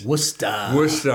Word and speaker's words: Worcester: You live in the town or near the town of, am I saying it right, Worcester, Massachusetts? – Worcester. – Oh, I can't get it. – It's like clam chowder. – Or park the car, right? – Park the car or clam chowder.